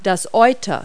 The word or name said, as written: udder